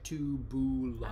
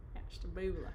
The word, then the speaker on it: Ashtabula: Someone said you pronounced it right. – Ashtabula. – Ashtabula. – Third, she says, accent on the third syllable. – Boo. – Accent on the boo. – Ashtabula. – I always try to accent the boo.